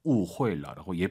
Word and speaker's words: 误会: 후이하지 마시고 UI 사이에 에 넣어서 你误会了. 误会了라고 예쁘게 발음하시면 좋을 것 같습니다.